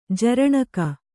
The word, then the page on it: ಜರಣಕ: jaraṇaka